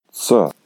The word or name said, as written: ce4